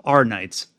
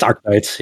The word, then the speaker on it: Arknights: Arknights. And I know Arknights is Arknights? I always pronounce it Arknight. I don't know if the K is like... – It's Arknights, yeah.